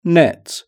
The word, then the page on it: net: British English